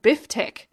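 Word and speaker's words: B(if)tech: So our first long song was by an Australian electronic act called Biftek, and it's spelt weird. It's B and then left parenthesis IF, right parenthesis tech, TECH.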